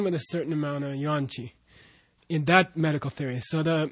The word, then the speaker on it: Yuan Qi: Is it Yuan Qi? Yang Qi? Wan Qi. Yeah. How do you say? Yeah. Yuan. Yuan Qi.